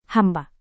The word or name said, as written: hamba